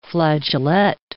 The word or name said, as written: flageolet